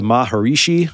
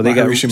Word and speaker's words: Maharishi: But they went so far as Mick and their road guy ended up going, is it the Maharishi? How do you pronounce that, Rosie? – Yeah, that sounds right. You know, Maharishi Mahesh Yogi, right.